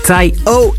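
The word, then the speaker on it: Taio: Taya Cruz, veel radioluisteraars en wij radio-dj's struikelen al jaren over zijn naam, maar moet je weten dat de officiële uitspraak simpelweg Ty-O is. Hij noemde zelfs zijn derde album in 2012 Ty-O om mensen dus te helpen met die uitspraak.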